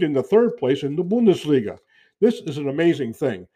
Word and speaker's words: Bundesliga: Okay, let's move south and look at the Bundesliga and Bayern Munich. I should say Bundesliga. I don't want to apologize to my German friends for that mispronunciation. Let's say Bundesliga and Bayern Munich at 45 points leading.